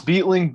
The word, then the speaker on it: Beitling: It's Beitling, but you're all good.